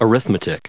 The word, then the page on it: arithmetic: arith*me*tic; American English